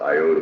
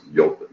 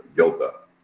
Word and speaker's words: iota: I, everybody in English thinks it's pronounced iota, but it's not. If you go to Greeks and, and study the Greek language, it's pronounced yota, yota, which is like the Hebrew yod.